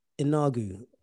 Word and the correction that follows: Ngannou: I'm not pronouncing his name right. – Ngannou.